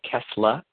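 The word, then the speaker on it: Kassler: It's actually spelled Kessler, K-A-S-S, I think it's L-E-R, but they say Kessler.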